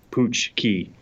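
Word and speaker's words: pączki: a giant billboard that would say it's Punchki season, and it would say underneath it, Punchki.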